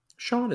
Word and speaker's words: Shaun: Um, Shaun isn't bad either.